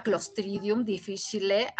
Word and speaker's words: Clostridium difficile: Pero hay una bacteria que es terrible, es mortal, se llama Clostridium difficile, así se pronuncia tal cual.